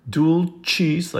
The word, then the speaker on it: Dulcis: O Sanctissima, O-P-E-sima, Dulcis, like cheese sandwich, Dulcis Virgo Maria.